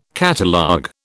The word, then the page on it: catalogue: British English